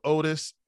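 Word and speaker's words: Odis: When do you get into, so you get, your first band was called, I want to say it was called Otis. I don't know if I heard that right. – Yeah. – Okay. Oh, is that, yeah. Am I saying that right? – Yeah, you're right.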